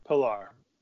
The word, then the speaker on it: Pillar: I see it pick 307, Kevin Pillar, how do you say that? But Pillar.